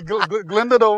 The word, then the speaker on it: Glendalough: How do you say it? – Glendala. – Oh, he knows I'm a man of being able to pronounce. – Because I was going to call it Gandalf for a second. Okay, Glendala works.